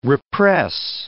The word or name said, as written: repress